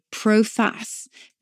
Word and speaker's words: proface: And before then, in the Middle Ages, we would sometimes say proface, P-R-O-F-A-C-E, but it's spelled like proface.